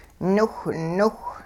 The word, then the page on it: niwhniwh: hupa